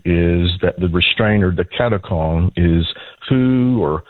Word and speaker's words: Katechon: How do you pronounce this, and what is Katechon? – Katakon. Katakon. – Katakon.